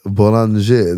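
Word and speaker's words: Bollinger: Boulanger, det är så man uttalar egentligen. Jag tror jag inte sa det så.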